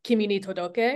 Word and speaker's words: Kimi ni Todoke: Like I watched a Japanese drama version of Kimi ni Todoke.